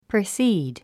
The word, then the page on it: precede: prisíːd; プリスィード